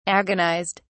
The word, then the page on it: agonised: British English